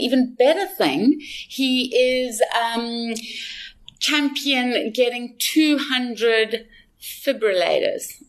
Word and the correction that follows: defibrillators: I can never pronounce that word. – defibrillators. – Did I say it right? That's it. Defibrillator. – Too many L's and R's in that for you. Defibrillators.